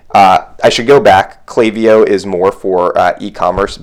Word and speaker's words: Klaviyo: some people people pronounce it Klaviyo uh, which is how we originally pronounce it until we learn that the proper pronunciation is Klaviyo um, so one of the reasons we love Klaviyo is because you can create unique flows.